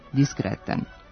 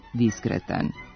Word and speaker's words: diskretan: U svakodnevnom govoru, ali i u govoru radijskih i televizijskih novinara, ova reč obično se izgovara s kratkouzlaznim akcentom na drugom slogu, diskretan. Međutim, u rečniku se može videti da njen akcenat jeste kratkouzlazni, ali na prvom slogu i glasi diskretan.